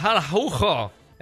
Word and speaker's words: Araujo: Si pronuncia Araujo. Gabriele, Luca da Barcellona, con Jay che diventa una C un po' aspirata. Araujo, una cosa del genere, voto Araujo, si avvicina Araujo.